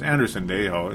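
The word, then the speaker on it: Sendejo: They kind of hinted that there's a small possibility he might be an odd man out because of a guy like Andrew Sandejo. Andrew Sandejo. Yay, I'm saying it right now. I like Sandejo, you know. No, it's Andrew Sandejo. It's not as hard as it looks.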